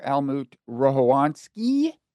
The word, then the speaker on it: Almut Rohowanski: And you are Almut Rohowanski, give or take a mispronounced syllable. – Well done.